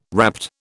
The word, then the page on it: rapt: British English